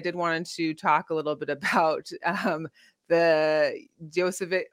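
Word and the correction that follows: Jokić: I can't even say his name. – Oh, Jokic. So.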